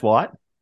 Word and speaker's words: Whyte: So, White, WHYTE, N White. – Yes, that's how you pronounce White.